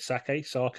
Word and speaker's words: sake: So next door to it was a brand new sake. How would you say it? Sake? – I think, isn't it? – Sake. Yeah.